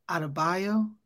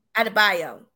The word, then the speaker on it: Adebayo: And in addition to that, Quincy, Adebayo, I never can say his name right. – Adebayo? – Adebayo, thank you.